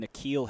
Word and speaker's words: N'Keal: N'Keal, is that what we're going with? – N'Keal Harry.